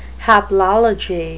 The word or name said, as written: haplology